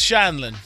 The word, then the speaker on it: Scheindlin: Anyway, Judge Judy Scheindlin. Am I pronouncing that correctly? – Yes, Scheindlin.